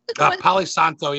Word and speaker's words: Palo Santo: I ran out of sage, so I have how do you say that? Paladins. – Palo Santo. Yeah, holy, I would get confused.